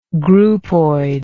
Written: groupoid

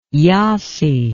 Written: yassy

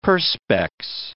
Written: Perspex

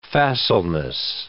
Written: facileness

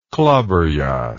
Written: klaberjass

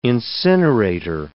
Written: incinerator

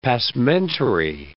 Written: passementerie

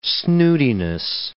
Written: snootiness

